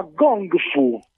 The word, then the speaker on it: Gong Fu: Wuxpian, ho detto bene? – Si chiama Gong Fu. – Ah, Gong Fu.